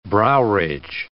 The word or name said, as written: browridge